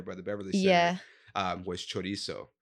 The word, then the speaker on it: chorizo: Chorizo.